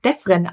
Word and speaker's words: Defren: Und ich freue mich, dass mir heute Nicole Defren, Defren, Defren, wie spricht man dich eigentlich aus, Nicole? Lustig, dass du es fragst. Es wird Defren ausgesprochen.